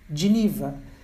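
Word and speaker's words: Geneva: Pois bem, tudo deriva da palavra genebra em inglês, pronunciada giniva, que foi reduzida a gin para facilitar a pronúncia e acabou virando gin em português.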